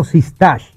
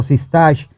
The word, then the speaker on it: Sistachs: Vamos a recibir a nuestro invitado y nuestro amigo Pedro Sistach. Yo lo dije bien, ¿verdad? Así es. Eh, Pedro Sistach,